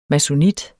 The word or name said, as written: masonit